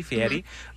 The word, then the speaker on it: Fieri: I say it correctly, Fieri.